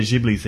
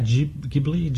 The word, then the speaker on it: Ghibli: Ghibli? Ghibli? – It's, oh, gosh. I've heard it both ways, and the Italian pronunciation, I think, is Ghibli, but they all say Ghibli, which is wrong.